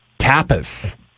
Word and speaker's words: tapas: T-A-P-A-S. – Tapas, not tapas. It's tapas.